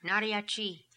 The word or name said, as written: Naria Chi